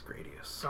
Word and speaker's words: Gradius: Rhymes with radius. Gradius.